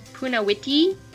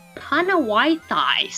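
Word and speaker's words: Punnawithi: So, you know, like, there's one stop, it's Punawiti, but it looks like, if you don't know how to pronounce it, it looks like it says Panawaitai.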